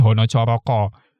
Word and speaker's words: Hana Caraka: Konon katanya, itu karena bahasa Jawa tuh akarnya dari Hono Coroko. Hono Coroko itu kan ditulisnya Hana Caraka sebenarnya, tapi cara baca standarnya Hono Coroko.